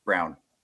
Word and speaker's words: Huchet: It's actually pronounced Sarah Brown. Brown. Brown. Brown. Yep. Brown. Yep. Brown. H U C H E T. Brown. In in French is Brown.